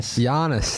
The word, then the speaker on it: Giannis: Is it, how do you pronounce Giannis? Bro, I don't freaking know. – Giannis, there you go.